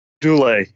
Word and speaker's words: Dulé: Duell, is that how you say his name? – Dulé, yeah.